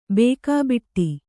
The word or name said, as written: ಬೇಕಾಬಿಟ್ಟಿ